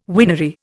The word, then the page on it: winery: Indian